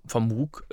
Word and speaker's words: Moog: Das klingt auf Deutsch, würde es mal doof klingen. Ne? Ich habe den Moog Sub 37. – Moog. – Ja. Ja, deshalb spricht man das halt immer so.